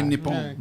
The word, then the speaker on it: Nippon: That's how the locals say it. – Konnichiwa. – They say Nippon.